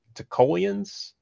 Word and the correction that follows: Talokans: Talokans, there you go.